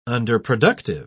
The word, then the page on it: underproductive: British English